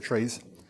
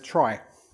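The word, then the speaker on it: trie: Says the term was coined 2 years later by Edward Fredkin, who pronounced it as tree, as we said, because the middle syllable of retrieval. However, other authors pronounce it as try, as we said, in an attempt to distinguish it verbally from tree.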